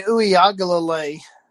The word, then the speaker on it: Uiagalelei: You know, DJ Uiagalelei, yeah, I said his name right.